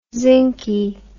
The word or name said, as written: zincky